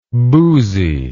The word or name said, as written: bousy